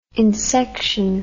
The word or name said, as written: insection